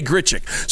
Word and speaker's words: Grichuk: It's spelled Grichuk. And I've never known I've heard people say Grichuk, and I never knew if that was right. So now I know officially it's right. You say Grichuk.